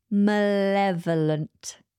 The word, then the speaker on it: malevolent: Okay, moving on to number four, and it is malevolent. Malevolent. This is an adjective, and it is a word that I really struggled for a long time to pronounce. Malevolent. This adjective is spelled M-A-L-E-V-O-L-E-N-T, malevolent, malevolent. This means causing or wanting to cause harm or evil.